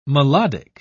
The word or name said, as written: melodic